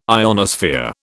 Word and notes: ionosphere: British English

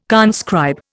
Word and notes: conscribe: Indian

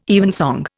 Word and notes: evensong: Indian

